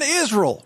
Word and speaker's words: Israel: And then Israel. We're going to go to Israel last. All right, getting back to Alexander Dugin. He says, quote, the collapse of Israel, notice three syllables, is inevitable.